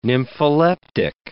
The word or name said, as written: nympholeptic